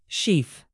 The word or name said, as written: sheath